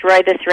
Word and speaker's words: rooibos: The other thing is rooibos red tea. It's, you pronounce it like the man's name, Roy, and then school bus bus, and it's spelled R-O-O-I-B-O-S, rooibos.